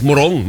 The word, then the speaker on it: Morong: Ano ba ang tamang bigkas din sa kanila? Morong o Morong? Kasi may sa Rizal, meron din eh. O, para sa parehong bigkas, Morong, mabilis.